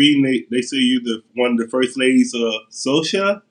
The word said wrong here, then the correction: Soca: It's Soca. Soca.